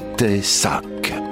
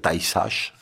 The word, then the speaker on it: Tay-Sachs: Il s'agit s'agit d'une affection entre toutes redoutée, car constamment invalidante et constamment mortelle, l'idiotie amorotique ou maladie de Tessac. Alors Tessac, ça s'écrit T-A-Y-S-A-C-H-S. peut être dire Taïsach, mais moi je dis Tessac.